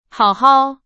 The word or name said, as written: 好好